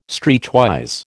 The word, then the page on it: streetwise: American English